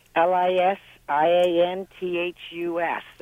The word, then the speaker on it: Lisianthus: I have a question about Lysanthius. I don't know if I'm pronouncing that correct or not. L I S I A N T H U S, the beautiful little blue flower. – Lisianthus. – Yes. – Yep, got it.